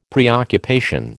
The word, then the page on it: preoccupation: American English